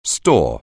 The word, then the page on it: store: anglais